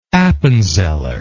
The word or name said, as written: appenzeller